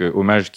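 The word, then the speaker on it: Omaj: cette entreprise donc hommage qui s'écrit O M A J c'est une contraction inventé du, mot, euh, du mot français HO2MAGE.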